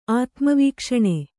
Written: ಆತ್ಮವೀಕ್ಷಣೆ

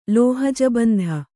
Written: ಲೋಹಜಬಂಧ

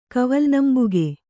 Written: ಕವಲ್ನಂಬುಗೆ

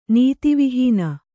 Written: ನೀತಿವಿಹೀನೆ

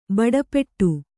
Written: ಬಡಪಟ್ಟು

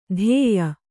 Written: ಧೇಯ

